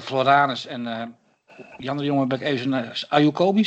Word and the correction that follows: Akuyobi: zeg ik dat goed? Die naam? – Akuyobi.